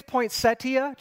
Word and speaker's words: poinsettia: Is it poinsettia or poinsettia? Yeah. What is it? Yeah. Both? Yeah. Technically, it's poinsettia, just for those of you who didn't know that.